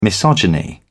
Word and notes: misogyny: British English

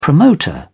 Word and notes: promoter: British English

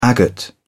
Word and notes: agate: British English

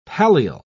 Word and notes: pallial: British English

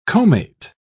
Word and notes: comate: British English

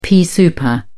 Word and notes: pea souper: British English

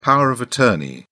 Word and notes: power of attorney: British English

